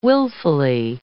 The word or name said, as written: willfully